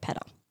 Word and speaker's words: Pedder: Pedder. – P-E-D-D-R. Pedder, like pitter-patter, but like Pedder.